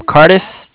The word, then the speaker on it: Cardiff: Yeah, it's Cardiff. Cardiff is spelled like C-A-R-D-I-F-F, like Frank.